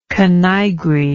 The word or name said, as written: canaigre